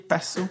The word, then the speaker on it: Passau: P-A-S-S-A-U. OK, Passo.